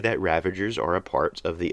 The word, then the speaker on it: Ravagers: One of my pet peeves is when people mispronounce Ravagers as Revengers, as if these are almost like Avengers, but instead of avenging, they're revenging. These are Ravagers. They ravage. They don't revenge.